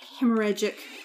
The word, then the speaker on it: hemorrhagic: Skipping ahead to the 1980s, a severe hemorrhagic... I did say that right, right? – Yes, you did.